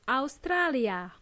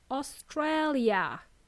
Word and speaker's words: Australia: In English, Australia has three A's, three letter A's. And they are all pronounced differently. In Spanish, as well as in Serbian, or most other languages, we simply say Australia. In English, it's Australia. Australia.